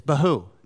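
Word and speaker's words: bohu: The other word, which we translate as void, rhymes with it. It's bahu, tahu and bahu.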